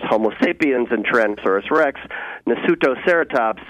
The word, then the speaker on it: Nasutoceratops: Is it Nasutoceratops? – Pretty good. Nasutoceratops is the way we say it.